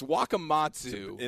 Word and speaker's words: Wakamatsu: I couldn't say his name properly. This is the only drop we have from this, but this is what I tried to say. Makuwatsa. – Wow. – It's Wakamatsu.